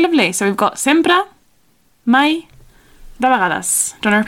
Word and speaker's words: mai: Okay, if you want to go the other way and say never, that word never is mai. – Mai. – Mai. Okay, it's spelled M-A-I.